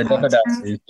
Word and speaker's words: Devadasis: is it Devadasis? Is that the Devadasis.